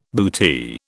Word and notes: bootee: British English

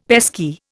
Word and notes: pesky: Indian

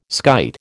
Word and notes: skite: American English

